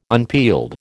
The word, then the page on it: unpeeled: American English